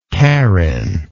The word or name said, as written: Carin